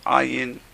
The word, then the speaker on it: ayin: So ayin is related to the word ani. So the word ayin with an R, with an ayin. You got to pronounce it gutturally.